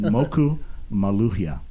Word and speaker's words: Moku Maluhia: I'm going to introduce them in a minute, but we're going to talk about his new album, and then see if I'm saying it right, my pronunciation. Moku Maluhia. – That's right. Very Moku, good.